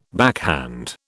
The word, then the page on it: backhand: British English